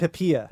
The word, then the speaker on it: Pipia: Well, actually, the correct pronunciation would be, well, the American is Pipia. – Okay. – That's the way we say Pipia. – I said Pipia. – You did.